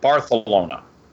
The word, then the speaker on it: Barcelona: And then you go and you find out it's Barcelona, where all they speak with a lisp. Oh. So they pronounce it Barthelona.